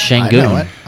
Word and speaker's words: Şengün: Is that how you say it? – Yeah, it's Şengün.